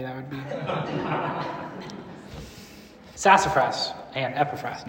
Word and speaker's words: Epaphras: And then in Philemon, Paul says, Epaphras.